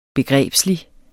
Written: begrebslig

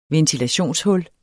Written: ventilationshul

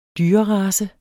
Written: dyrerace

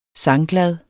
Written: sangglad

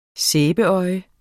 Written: sæbeøje

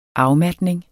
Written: afmatning

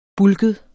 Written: bulket

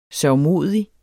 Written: sørgmodig

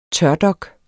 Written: tørdok

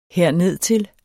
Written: hernedtil

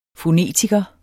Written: fonetiker

